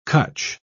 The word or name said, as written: cutch